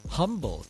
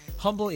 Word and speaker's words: Humble: Box 2511, Humble, Texas 77347. Humble is it's really pronounced humble, but you know, humble. Silent H, but it's Humble, H-U-M-B-L-E.